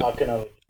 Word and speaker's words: Archonology: What I was hoping to specifically address is your, now, how do I say it? Archonology? How do you say it? – Archonology. – Archonology. – Like Chardonnay, the H is silent.